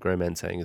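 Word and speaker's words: Gros Manseng: Mark Walpole, very, very well-respected viticulturist, winemaker, but he's a very respected viticulturist, and it's late harvest Gros Mansang, and Gros Mansang is the variety. – How do you spell that? – So I think G-R-O-S. – Yeah. – And then second word is Mansang, M-E-N-S-A-N-G.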